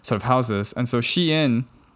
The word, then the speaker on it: Shein: Shein, please. – I don't even know how to pronounce it. – Shein. Right? Break it up. – But I mean... Okay, do you say Calvin Klein or do you say Calvin Klein? – Calvin Klein. – Exactly. And it's E-I-N. So... – Yeah, but it's... The company thought people would pronounce it as Shein. Shein. Yes. – Oh God. Okay. – No, not Shine.